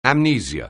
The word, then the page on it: amnesia: anglais